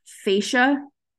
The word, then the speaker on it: fascia: So, right where your ear meets your neck, this here, this fascia, that's how you say it, the muscle fibers get really tense here, especially if you're gritting all day long.